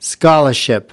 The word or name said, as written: scholarship